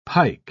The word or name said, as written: pike